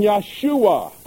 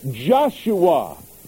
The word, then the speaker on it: Yeshua: Which came with Zerubbabel and Yeshua, or Joshua. Either pronunciation is appropriate.